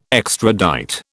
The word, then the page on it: extradite: British English